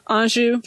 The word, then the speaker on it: Anjou: So and Anjou.